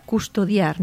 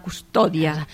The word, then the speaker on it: custodia: Per exemple, la paraula custodiar, no? Doncs custodia, no? – Exacte. – No es diu la custòdia. – Custodia, clar, és el verb conjugat, exacte.